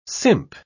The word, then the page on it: simp: British English